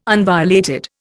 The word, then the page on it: unviolated: Indian